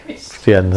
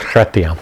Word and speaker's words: Chrétien: I was pronouncing Christian. Yes, it's Chrétien.